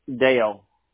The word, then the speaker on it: Dale: Your name is Clint, right? Did I get it correctly? – Dale. – I'm sorry. It's Dale. D-A-L-E? – Yep.